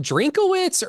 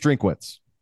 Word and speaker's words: Drinkwitz: Which, speaking of Missouri, is it Drinkowitz or Drinkwitz? Like, how do we say this motherfucker's name? Anybody? – I believe it's Drinkwitz. – Drinkwitz.